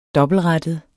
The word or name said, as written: dobbeltrettet